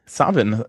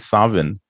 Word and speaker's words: Samhain: Savin, das ist der keltische Name dessen, was dann später mal Halloween geworden ist. Aha. Äh, wenn das Samhain steht, das heißt eigentlich Savin, weil das ja. keltisch ausgesprochen wird.